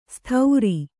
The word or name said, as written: ಸ್ಥೌರಿ